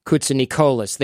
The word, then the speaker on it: Koutsinikolis: That's apparently how you pronounce that. Koutsinikolis. It's a very Greek name.